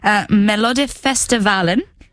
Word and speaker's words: Melodifestivalen: It uses Melodifestivalen.